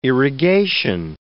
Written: irrigation

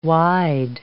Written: wide

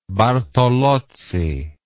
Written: Bartolozzi

